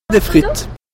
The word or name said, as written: Frites